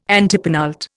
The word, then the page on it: antepenult: Indian